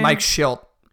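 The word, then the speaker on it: Shildt: Mike Schilt.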